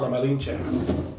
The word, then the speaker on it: La Malinche: So there was a woman named, or she went by the name La Malinche. I'm probably saying it wrong. – La Malinche.